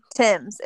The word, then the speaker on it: Thames: Thames. It rhymes with gems. So we're both saying it wrong.